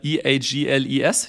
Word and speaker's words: Eagles: Machen diese EAGLES, ich habe es richtig pronounced, im Gegensatz zur Bürgermeisterin. Also da wurde es ja so unfassbar laut.